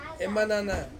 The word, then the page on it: manana: Ansus